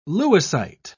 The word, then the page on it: lewisite: British English